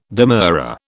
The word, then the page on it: demurrer: British English